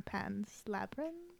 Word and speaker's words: Pan's Labyrinth: than Pan's Labyrinth. – Yeah, you said it right.